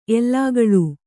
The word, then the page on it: ಎಲುಗುಳಿ: kannada